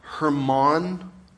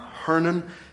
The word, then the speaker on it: Hermon: This is what will be coming off of what's called Mount Hermon. You've heard it pronounced, or Mount Hernan. However you want to pronounce it, doesn't matter to me.